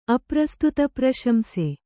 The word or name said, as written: ಅಪ್ರಸ್ತುತಪ್ರಶಂಸೆ